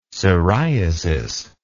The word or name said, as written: siriasis